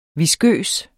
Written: viskøs

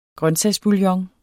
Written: grøntsagsbouillon

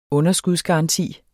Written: underskudsgaranti

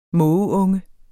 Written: mågeunge

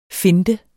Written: finte

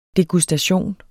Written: degustation

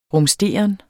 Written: RUMSTEREN

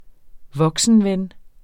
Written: voksenven

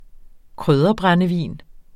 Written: krydderbrændevin

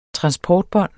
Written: transportbånd

